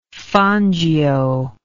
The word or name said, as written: Fangio